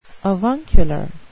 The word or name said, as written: avuncular